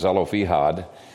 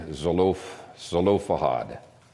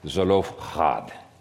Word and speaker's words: Zelophehad: Well, the daughters of Zelophehad, I used to mispronounce this as Zelophehad, but it's closer to the Hebrew to say Zelophehad. Actually, the Hebrew is Zelophehad, with a lot of phlegm in it.